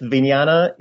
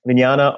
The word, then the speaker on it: vijnana: Yes, vijnana is, or as it's pronounced in Khmer, vijnan. Vijnana are there, and that is typically roughly considered sort of the moral consciousness.